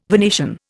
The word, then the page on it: Venetian: Indian